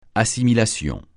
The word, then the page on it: assimilation: francais